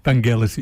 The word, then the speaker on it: Vangelis: Vangelis, it should be, with a hard G.